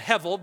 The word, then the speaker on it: hevel: The vowel markings on this indicate that's a soft B, soft beit. That's why you get Hevel or Hebel. So I, am going to call it Hevel because that's really, really, I think, more accurate how to pronounce it.